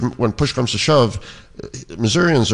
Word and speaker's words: Missourians: and Missourians, is that how you say it? – Yep, sure.